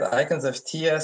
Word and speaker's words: Icons of Theia: If you want to share first about Icons of Theia, I feel like I always say it wrong. So remind me how to pronounce it and then give us the elevator pitch for it. – Yeah, I did it right. Icons of Theia.